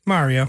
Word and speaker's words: Mario: It's not Mary with an O. – Yeah, Mario. That's a good point. Yeah, I never really thought about that. How does he say it in the movie? – Mario. – Mario. – Mario. – That's how you pronounce it. That's the correct way.